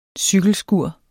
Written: cykelskur

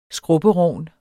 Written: skrubberogn

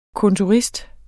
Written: kontorist

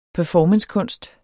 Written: performancekunst

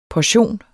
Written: portion

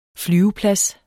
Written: flyveplads

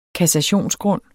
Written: kassationsgrund

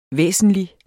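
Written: væsentlig

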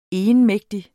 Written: egenmægtig

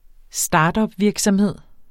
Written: startupvirksomhed

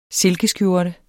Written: SILKESKJORTE

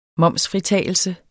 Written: momsfritagelse

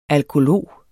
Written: alkolog

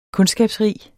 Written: kundskabsrig

